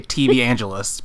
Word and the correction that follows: televangelist: because I can't say that word. TV angelists, like, on, you know, back in the 70s and 80s. But I guess the right way to say it is televangelist, right? – Yes, you got it.